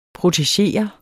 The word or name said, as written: protegere